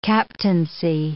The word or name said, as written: captaincy